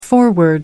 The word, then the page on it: foreword: American English